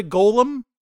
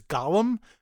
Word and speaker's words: golem: I'm going to call it a golem. I'm not going to pronounce it the way they do, which is Gollum, because Gollum is a creepy little guy from The Lord of the Rings.